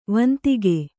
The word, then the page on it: ವಂದಕ: vandaka